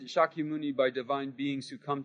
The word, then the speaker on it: Shakya Muni: Shakya Muni. Hear where the accent falls? Shakya Muni. Right? First syllable because it's long.